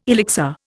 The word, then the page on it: elixir: Indian